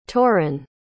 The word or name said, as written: torin